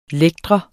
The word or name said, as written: lægte